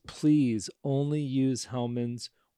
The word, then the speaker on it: Hellmann's: East of the Rockies, it's Hellmann's.